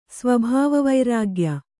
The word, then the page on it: ಸ್ವಭಾವವೈರಾಗ್ಯ: sva bhāva vairāgya